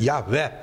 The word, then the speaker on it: Yahweh: So the word Jehovah, as we sometimes mispronounce it, Yahweh would probably be the best, but even the Jewish people, the Hebrews, would never pronounce it.